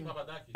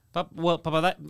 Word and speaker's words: Papadakis: It's funny how you Greeks don't pronounce sometimes the S at the end. – It depends how you're saying it. – Papadaki. – Papadakis, because it's the way he's saying it, but it's Vicky Papadakis.